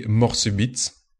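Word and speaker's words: Mort Subite: It's very difficult to pronounce that in English when you're not really used to it. In French, it would be Morsubit.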